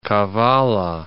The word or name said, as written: Kavalla